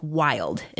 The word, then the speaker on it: Wyld: Mine is a line of CBD gummies from a company called Wild, and it's spelled W-Y-L-D.